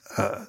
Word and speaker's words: Alþingi: How do you pronounce it? Do you know? – Alting. – I should know this. It's Alting. Alting.